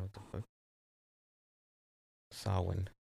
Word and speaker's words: Samhain: But yeah, Sam Hain and uh, even to go even further, uh, Sam Hain is actually pronounced Sawin.